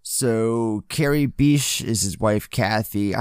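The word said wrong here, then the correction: Bishé: I hope I'm pronouncing her name right. – I think it's Bichet. – Bichet, okay. The hyphen over the E, that makes sense.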